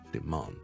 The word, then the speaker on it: demand: 好了，跟着还有多些例子的，譬如我们说 demand， D-E-M-A-N-D，一个需求啦。英式英文我们是 demand， R-R-R， demand，跟着 and， demand， demand， demand， demand。